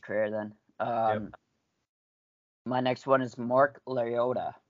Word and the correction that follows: Loretta: Lorettta. Maybe I'm saying that wrong. – Mark Loretta? – Yeah, correct. Yeah.